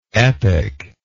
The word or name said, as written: epiclike